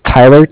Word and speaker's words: Tyler: My name is Tyler, T-Y-L-E-R. – Okay. – And I'm calling from Bank of Cardiff. It's spelled C-A-R-D-I-F-F, as in Frank Frank.